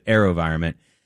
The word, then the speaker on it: AeroVironment: It's spelled A-E-R-O and then a capital V, environment, like environment, but AeroVironment.